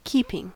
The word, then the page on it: keeping: American English